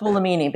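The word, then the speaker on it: Buolamwini: I think it's pronounced Bulamini, but yes.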